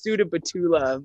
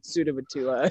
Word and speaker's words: Sudabattula: It's Sudabattula, but it is such a, I mean, Hakuna Matata, Sudabattula.